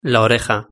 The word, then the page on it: la oreja: Spain Spanish